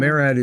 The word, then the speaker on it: MARAD: Is it MARAD? Is that how it's called? MARAD is the pronounceable acronym for the Maritime Administration.